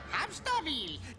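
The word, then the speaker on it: Hamsterviel: Hamsterwil. Det udtales Hamsterwil.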